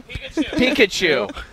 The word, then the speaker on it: Pikachu: Am I saying that right? – Pikachu.